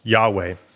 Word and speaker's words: Yahweh: They would write it like this: H-W-H. So we're not quite sure how they would have pronounced it at that time, but most people agree that it was pronounced Yahweh.